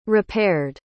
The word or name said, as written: repaired